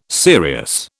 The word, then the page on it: serious: British English